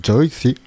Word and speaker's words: Joyce: qui s'appelle Joyce, qu'on prononce Joyce, en brésilien,